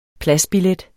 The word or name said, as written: pladsbillet